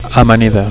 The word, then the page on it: amanida: Catalan